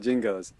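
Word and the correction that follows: Jinjo: I believe it's pronounced jinjos for starters.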